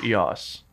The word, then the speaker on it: EOS: So the Canon people, I did an interview with them and they were like, it's pronounced EOS. – Oh. – I'm like, that sounds kind of dorky. I'm not going to call it EOS.